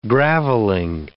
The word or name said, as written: gravelling